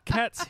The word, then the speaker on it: Kat: So Kat's here. – That's Kat with a K, by the way, Kyron.